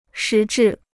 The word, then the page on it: 实质: shí zhì